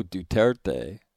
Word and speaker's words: Duterte: Earlier in his term, Philippine President Rodrigo Duterte, Grant taught me how to say his name, wanted to end military exercises with the U.S.